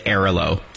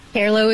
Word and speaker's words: Airalo: That leads us nicely to Arelo. So this is, as I said off the top, one of the biggest names in the eSIM space. It's spelled A-I-R-A-L-O. Tell us about the company. – Arelo is the world's largest eSIM platform.